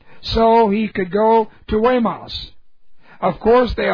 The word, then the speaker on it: Guaymas: He stopped by the village on Tuesday afternoon on his way to Guaymas, and I knew how to pronounce that only because I've been there. G-U-A-Y-M-A-S. I'd have torn that word up. But I learned how to say it. It's Guaymas.